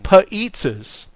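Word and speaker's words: P'zza: Now, this episode is even better because it deals with 7-Eleven and paizzas. That's right, paizzas. – Paizzas or paizzas? How do you say it? – Paizzas. – Paizzas. – A P apostrophe eat. ZZA. Paizza.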